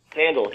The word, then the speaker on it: Sandall: Sandal, just like you know, just like a flip flop.